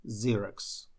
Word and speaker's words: Xerox: В своем курсе я рассказываю, что когда слово начинается на букву X, то оно читается звуком Z в английском. Поэтому Xerox, Xerox, Xerox.